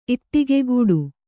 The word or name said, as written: ಈಡಿಗ್ಗೌಡ